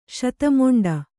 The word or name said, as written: ಶತಮೊಂಡಿ